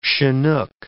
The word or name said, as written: Chinook